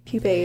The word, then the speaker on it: pupa: How do you even pronounce it? Pupa? – Pupae. Oh, wait. Yeah, pupa. – Pupa. – Pupae is plural.